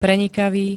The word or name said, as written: prenikavý